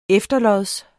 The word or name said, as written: efterlods